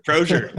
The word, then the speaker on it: Crozier: No, you made me sound way fancier than I am. I'm from the Midwest, so it's just Crozier. – Crozier.